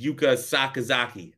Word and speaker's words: Yuka Sakazaki: And it's heavily rumored that the match for the women is going to be Athena versus Yuka Sakazaki.